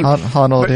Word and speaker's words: Honnold: I mean, you talk about this guy, Alex Honnold. I don't know how you pronounce the last name. – Honnold, yeah.